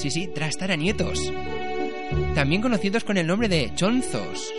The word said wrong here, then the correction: choznos: O choznos, perdón. Es que cuesta hasta pronunciarlo. Pues sí, sería eso, los choznos o choznas.